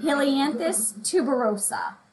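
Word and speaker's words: Helianthus tuberosus: And I'm going to try to say this correctly. So Michaela, if I don't do it properly, you got to correct me. Helianthus tuberosa. – Yeah, that sounds right.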